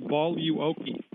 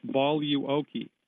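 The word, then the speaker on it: Boluoke: It's called Voluoki. You spell that B like baby, O like Oscar, L like Larry, U like uniform, O like Oscar, K like kilo, E. Voluoki.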